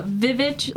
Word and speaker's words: VVitch: The Vivitch, like the, you know which what? Movie? The Vivitch, like it's spelled V-V-I-T-C-H.